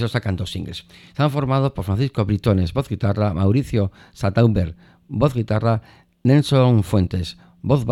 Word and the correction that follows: Santander: bajo, y Jaime Santander, ¿no? Santander, Mauricio Santander, el voz guitarra, que antes lo pronuncié mal.